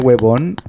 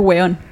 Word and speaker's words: huevón: ¿No es que es distinto como lo dicen ellos? – ¿La pronunciación? – Con, ellos con B larga, nosotros como con... – Huevón. – Sí, eso. – Nosotros es hueón.